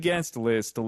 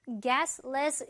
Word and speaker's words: guest list: Here is the guest list, Liz. – 자, 요거 약간 guestless 이런 식으로 들리면서 좀잘 알아듣기 어려웠겠지만 guest list였거든요. 빨리 발음될 때 트 발음은 많이 날아갑니다.